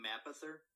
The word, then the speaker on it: Mapother: William Mapother? – Okay, that's what I said.